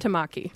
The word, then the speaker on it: Tamaki: I don't know if I'm pronouncing that right. – Tamaki. – Tamaki. Yeah.